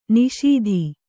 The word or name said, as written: ನಿಷೀಧಿ